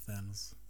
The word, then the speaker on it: Thanos: Jag, jag, jag, jag förutsätter att det eh, är serietidningar så det finns ingen bestämd, inget bestämt sätt att uttala det men de säger ju Thanos i filmen så att jag går på Thanos.